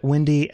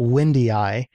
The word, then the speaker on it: WINDII: And the instrument was called Windy, W-I-N-D-I-I. Pretty sure they pronounced it Windy and not Windy-I.